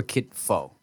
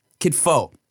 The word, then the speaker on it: kitfo: So one thing they'll do is this thing called kitfu, right? Where it, let me see if it's kitfu or kitfo. Let me make sure I'm saying that right.